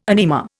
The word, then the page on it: anima: Indian